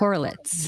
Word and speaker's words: Korelitz: Korelitz.